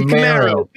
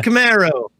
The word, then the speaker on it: Camaro: Camaro. Bitchin' Camaro.